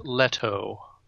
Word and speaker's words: Leto: It's pronounced Leto.